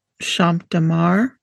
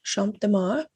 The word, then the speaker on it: Champs de Mars: From her window, she could see the Eiffel Tower and the Champs de Mars. Is that how you say it? – I would say Champs de Mars. – Okay, Champs de Mars.